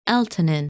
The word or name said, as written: Eltanin